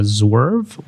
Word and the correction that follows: Zerve: or Zerve? Zerve. – Zerve.